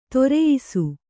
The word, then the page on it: ಧರಿಯಿಸು: Kannada